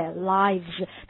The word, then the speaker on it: lives: 生活有很多用法，跟我读一下呢，life 啊，注意它的复数形式 lives，对不对？lives，大家注意一下发音的时候，请大家注意它的饱满的感觉，I，OK，lives。